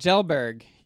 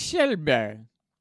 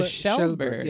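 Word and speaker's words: Kjellberg: Oh, you think it's Kelberg? – Yeah. – Oh, wait. We can listen to the pronunciation. – Let's do it. – Felix Schellberg. It kind of sounds like a Schellberg. – Yeah, Schellberg, yeah.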